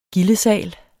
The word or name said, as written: gildesal